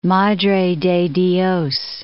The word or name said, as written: Madre de Dios